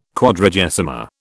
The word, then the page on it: Quadragesima: British English